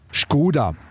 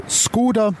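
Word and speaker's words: Škoda: Wenn man es ganz genau nimmt, dann müsste man die tschechische Marke ja auch tschechisch aussprechen. Also Skoda. Da das allen aber nicht so leicht über die Zunge geht, ist auch ein eingedeutschtes Skoda, zumindest bei uns, richtig.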